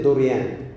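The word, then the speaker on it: دوريان (δωρεάν): في اليوناني معناها دوريان آه، آه، آه، تتنطق دوريان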